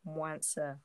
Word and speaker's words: Mwansa: And, like, my name's Mwansa, right? So, as in, it's said how it's spelt.